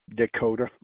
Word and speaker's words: Descoteaux: Dakota, like the state.